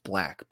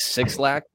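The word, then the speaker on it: 6LACK: Fifth is it's pronounced Black, but with a six at the beginning. – I call him six. – So I go with six lakh, but apparently it's pronounced Black.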